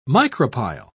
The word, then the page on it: micropyle: British English